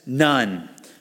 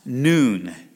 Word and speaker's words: Nun: Now it says none, but it's pronounced noon. And I'm going to pronounce it noon because it sounds weird to say Joseph, the son of a nun. It's like, I didn't know nuns had sons. I thought, you know, none meant none. And so they don't have any kids. And so it just sounds kind of weird. So it's Joshua, the son of Nun, is actually the pronunciation.